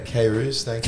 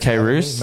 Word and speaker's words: Kairouz: Yeah, Kairouz. Kairouz.